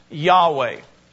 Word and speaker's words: Yahweh: It's because that word is being translated from the Hebrew word, which is spelt Y-H-W-H. And we believe that it's pronounced Yahweh. Yahweh is the proper name of Almighty God.